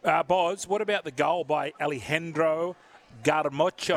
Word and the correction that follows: Garnacho: Am I saying his name correctly? – Garnacho. Is, yeah, Garnacho.